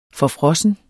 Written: forfrossen

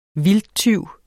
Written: VILDTTYVENE

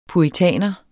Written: puritaner

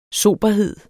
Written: soberhed

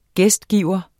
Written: gæstgiver

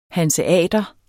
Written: hanseater

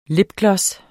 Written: lipgloss

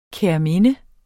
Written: kærminde

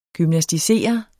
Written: gymnasticere

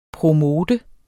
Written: promote

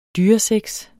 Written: dyresex

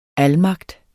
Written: almagt

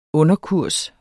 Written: underkurs

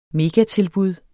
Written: megatilbud

